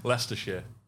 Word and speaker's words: Leicestershire: Well, how is it? – Leicestershire. It doesn't look like that at all. – Yeah, it looks like it's pronounced. – Yeah. The Americans are notorious for not getting the shires right. And I don't blame you because it doesn't look anything like Leicestershire.